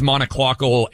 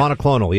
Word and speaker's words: monoclonal: Now let's talk about these monoclonal antibodies, and I'm sure I mispronounced that. – Monoclonal, yeah.